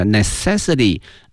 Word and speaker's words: necessity: 我们学过它的使用词哈，非常的熟悉 necessary，但是呢变成了名词哈，它的发音呢多少有些变化，叫 necessity，我们可以说空气、水阳光是我们生活的必须，我们可以说 sunshine, fresh air and waters are necessary for our life，那么也可以说呢 water, fresh air 等等 are necessity of our life。